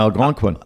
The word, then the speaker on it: Algonquin: In welcher In äh, Algonquin. Algonquin, ja, meine Betonung war falsch. Ja, ja, Algonquin.